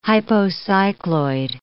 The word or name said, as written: hypocycloid